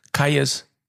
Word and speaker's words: Kyuss: Das ist das Synonym eigentlich tatsächlich, wenn du über die 90er und Desert und Stoner Rock redest, kommst du an Kajes nicht vorbei. Übrigens geschrieben K-Y-U-S-S. – Genau. – Ich habe die 20 Jahre falsch ausgesprochen. Sage ich aber nicht wie.